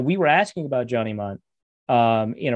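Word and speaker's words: Mundt: So some Vikings fans have started calling him Munt Cake, which I think is amazing. It's incredible. And his name is spelled like Bunt Cake. It's UNDT, right?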